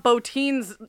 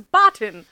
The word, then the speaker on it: Bottin: I was pronouncing Rob Bottin's last name wrong. I thought it was Botin.